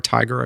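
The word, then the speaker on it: Tigar: It's T-I-G-A-R. It's T-Gar Tiger. I've never said his name, but I love that guy.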